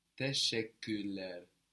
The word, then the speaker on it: teşekkürler: The word for thank you in Turkish is teşekkürler. Teşekkürler. – This is spelled T-E-S with a little tail to give a sh. Teşe-i again. K-U with the two dots R. L-E-R.